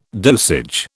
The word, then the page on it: dosage: British English